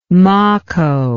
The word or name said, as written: maco